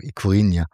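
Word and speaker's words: Ikurriña: Also es gab ja den Fall, da hast du, glaube ich, sogar einen Text drüber geschrieben, dass 50 Jahre nach dem verbotenen Ikurina, habe ich das richtig ausgesprochen? – Ikurina. – Oh, genau, auf das Feld geführt wurde.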